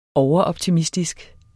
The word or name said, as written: overoptimistisk